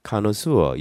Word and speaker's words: connoisseur: 프랑스 말이기 때문에 발음이 connoisseur 이렇게 발음이 나는 겁니다.